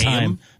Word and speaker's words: Haim: No, it is Haim. No, it is like time. Haim.